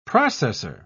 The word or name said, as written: processor